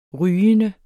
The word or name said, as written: rygende